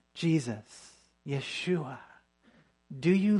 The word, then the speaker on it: Yeshua: Yeshua. It's with a Y sound.